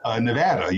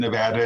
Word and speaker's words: Nevada: And then also Nevada. You said Nevada and not Nevada.